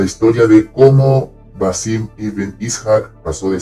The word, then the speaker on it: Ishaq: así se pronuncia, Ishaq, no Ishaq, Basim Ibn Ishaq, si bien él nació en otra ciudad, la ciudad de Samarra, él pasa su adolescencia en Bagdad.